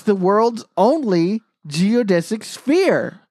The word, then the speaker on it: geodesic: So, speaking of geodesic domes, am I saying that right? – Yeah, of course you are.